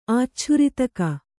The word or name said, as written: ಆಚ್ಛುರಿತಕ